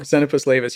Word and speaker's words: Xenopus laevis: Xenopus laevis, yeah.